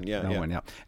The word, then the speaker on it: Melvoin: Melvoin.